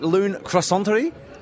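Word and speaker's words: Lune Croissanterie: It is Dr. Kate Reid, the owner of, am I saying this right, Loon Croissanterie? – That is so perfect.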